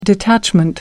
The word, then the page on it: detachment: American English